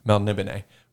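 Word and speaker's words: Melniboné: Matt Smith himself, though, really just reminds me of a character from a Michael Moorcock series of Elric of Melbourne. Or Melnibone, sorry. I always pronounce it wrong. Melnibone.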